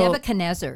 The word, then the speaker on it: Nebuchadnezzar: So Nebuchadnezzar is how I've heard it pronounced, but you're right. There are multiple, and you know what? It's all okay.